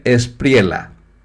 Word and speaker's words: Espriella: Otro tema, Colombia tiene nuevo presidente, es Abelardo de la Espriella, se escribe Espriella, pero se pronuncia Espriella, Abelardo de la Espriella, ganó en las urnas el domingo la segunda vuelta, el balotaje, en las elecciones presidenciales en Colombia.